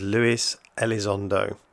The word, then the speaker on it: Luis Elizondo: For the record, the pronunciation is Luis Elizondo.